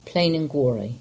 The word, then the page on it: Plain an Gwarry: English